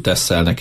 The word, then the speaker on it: Texel: Hivatalosan Texelnek van írva, de hollandul Texelnek ejtik.